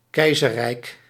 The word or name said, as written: empire